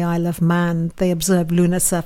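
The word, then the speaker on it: Lughnasa: So let's take a look at Lunasa. If you're wondering how to spell it, because it's a Celtic spelling, Irish spelling, it's L-U-G-H-N, Lu, N-A-S-A.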